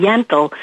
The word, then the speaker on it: Yentl: And I was doing Barbara Streisand and talking about doing Yentl. It's pronounced Yentl, not mental.